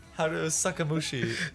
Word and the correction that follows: Haruhi Suzumiya: It's called The Melancholy of Haruhi Suzumiya, also known as Time to Butcher the Name.